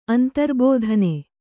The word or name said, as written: ಅಂತರ್ಬೋಧನೆ